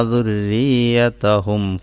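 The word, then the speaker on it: ദുർരിയതഹും (ذُرِّيَّتَهُمْ): ദുർരിയതഹും എന്നതാണ് അറബിയിലുള്ള വാക്ക്. ര എന്ന വാക്ക് അറബിയിൽ സാധാരണഗതിയിലില്ല. എന്നാൽ റി എന്ന ഉച്ചാരണമില്ല. റാന് ഫതഹ് കൊടുത്താൽ റ എന്ന് വായിക്കും. റാന്റെ മുകളിൽ വരയിട്ടാൽ ഫതഹ് കൊടുത്താൽ റ എന്ന് വായിക്കും. താഴെയാണ് വരയിടുന്നതെങ്കിലോ, കസ്റാണ് കൊടുക്കുന്നതെങ്കിലോ, റി എന്നല്ല വായിക്കുക, രി എന്നാണ് വായിക്കേണ്ടത്. റി എന്ന ഉച്ചാരണമില്ല, രി എന്നാണ് ഉച്ചരിക്കേണ്ടത്.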